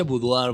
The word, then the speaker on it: boudoir: bueno, se pronuncia boudoir, boudoir. Ni siquiera boudoir se escribe.